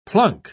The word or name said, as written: plunk